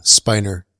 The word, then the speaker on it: Spiner: And then, so we had Brent Spiner. Right. Is that how you say it? Data. – Spiner.